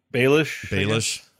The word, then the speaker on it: Baelish: Peter Baelish or whatever you say his name. – Baelish? – Baelish. Is that it? Yeah.